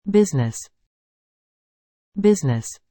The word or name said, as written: business